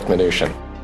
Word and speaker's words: Mnuchin: I'll go with Mnuchin.